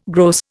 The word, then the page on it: gross: Indian